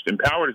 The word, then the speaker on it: EMPOWRD: Empowered is an application. It's free. And it's spelled EMPOWRD for anyone that wants to download it on Android or iOS.